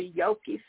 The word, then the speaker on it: Yoki: And recently I posted on YouTube, which you could go to Yoki Songmaker on YouTube. My name is spelled Y-O-K-I.